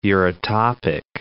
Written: eurytopic

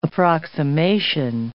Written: approximation

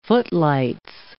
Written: footlights

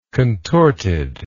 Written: contortedness

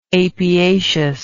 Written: apiaceous

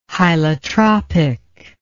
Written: hylotropic

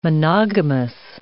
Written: monogamous

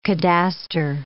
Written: cadastre

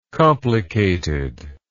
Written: complicatedness

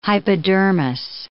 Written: hypodermis

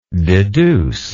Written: deducibly

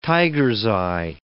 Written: tiger's-eye

